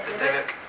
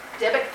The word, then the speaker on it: dybbuk: But that word Dybbuk, or Dybbuk, I'm not even quite sure how to say it. Dybbuk. – Dybbuk, thank you.